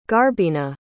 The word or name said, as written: garbina